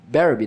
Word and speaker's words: Berube: o Craig Berube, né? É isso a pronúncia? – Exatamente, você foi muito bem no seu inglês.